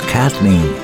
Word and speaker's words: Kathleen: And Max says, you know how we pronounce the name Kathleen? We leave out the H, and it's Kathleen.